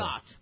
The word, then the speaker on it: Zagat: Is it Zagat or Zagat? – I don't know. Zagat. – It is Zagat. Okay. Did I pronounce it correctly? – You did.